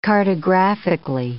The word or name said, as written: cartographically